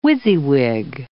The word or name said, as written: WYSIWYG